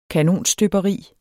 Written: kanonstøberi

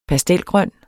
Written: pastelgrøn

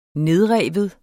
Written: nedrive

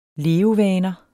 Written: levevaner

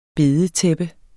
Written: bedetæppe

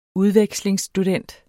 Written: udvekslingsstudent

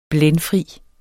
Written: blændfri

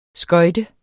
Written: skøjte

